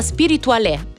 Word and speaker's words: Spirituale: Arriva Spirituale, si legge proprio così con l'accento, il nuovo singolo di Biagio Antonacci insieme a Saif, contenuto nel nuovo album Mustang.